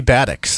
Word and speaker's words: Batiks: B-A-T-I-K-S? – It would be Batics. That is the correct way of pronouncing it.